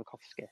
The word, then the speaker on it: Tarkowski: Tarkowski, because another reason I want to see it pushing up is because if Tarky does go, or oh, Tarkovsky as it's pronounced,